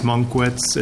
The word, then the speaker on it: Munkwitz: My name is Dennis Munkwitz. It's spelled M-U-N-K-W-I-T-Z, and it's pronounced just that same way.